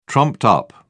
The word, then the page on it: trumped-up: British English